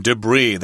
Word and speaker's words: debris: Now, we've got a word here, debris. That's a French word, so the S at the end is not pronounced.